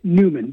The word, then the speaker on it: Neumann: So it's written Newman, N-E-U-M-A-N-N. – Right. – Newman. But I've heard it pronounced most often Neumann.